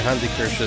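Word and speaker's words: Hansi Kürsch: Yeah, that's right, Hansi Kirsch is how you pronounce it, at least how I pronounce it.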